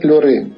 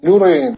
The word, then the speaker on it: LeWRAIN: Чем занимается проект LuRain? – Во-первых, скажи, как он правильно называется? – LuRain. – LuRain. Пишется LeWRAIN. – LeWRAIN. – Лев Раин, получается.